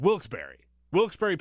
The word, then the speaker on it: Wilkes-Barre: It's Wilkes-Barre, but it's pronounced Wilkes-Barre, Pennsylvania.